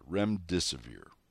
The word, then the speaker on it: Remdesivir: Remdesivir.